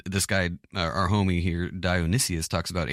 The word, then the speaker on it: Dionysius: Yeah, no, this is Dionysius or Dionysius. A judge. Judge O Dionysius. – One extra syllable in there.